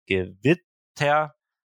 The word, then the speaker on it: Gewitter: Aber auch zum Beispiel bei Gewitter kann es die mittlere sein. Das wird nämlich Gewitter ausgesprochen. Aber es gibt auch ähm, Wörter, die werden auf der hinteren Silbe ähm, betont, wie zum Beispiel Gesicht.